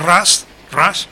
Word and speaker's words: Rush: Muy actual, se llama Rush, pero se escribe R-U-S-H...